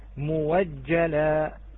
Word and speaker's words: مؤجلة: كلمة مؤجلة، مؤجلة هل اتصفت بالشروط الثلاثة أم لا؟ هل اتصفت بالشروط الثلاثة أم لا؟ نعم، فإذا تبدل فتلفظ موجلة، موجلة. أبدلنا إلى، أبدلنا هذه الهمزة إلى واو،